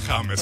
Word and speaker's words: James: Se pronuncia James.